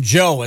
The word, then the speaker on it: Djo: B93.7, end of beginning from Joe. It's spelled D-J-O, but it's just pronounced Joe, apparently.